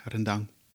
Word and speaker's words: rendang: En ik heb al gehoord dat het klemtoon meestal op de tweede lettergrepen ligt bij de uitspraak rendang.